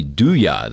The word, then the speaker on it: Dooya: We're now joined by Kyle Dowie of crypto arbitrage company Douya. That's spelled D-O-O-Y-A.